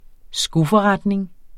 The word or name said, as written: skoforretning